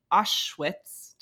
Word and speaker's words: Auschwitz: Auschwitz.